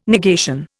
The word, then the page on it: negation: Indian